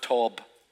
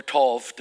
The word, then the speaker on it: Tob: Then Jephthah fled from his brothers and lived in the land of Tob, or Tov, depending on how you pronounce it.